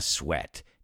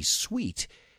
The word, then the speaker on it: sweat: So when something was really difficult, you say, I didn't even break a sweat. Ni me puse a sudar. Careful with the pronunciation. I've heard many students say sweet. And trust me, sweat is not sweet.